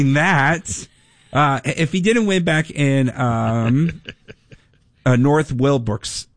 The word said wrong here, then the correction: Wilkesboro: so is that how you say it? – Yeah, Wilkesboro. – Wilkesboro.